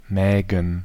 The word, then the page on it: Mägen: /mɛːɡən/